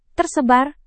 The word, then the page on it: tersebar: Indonesian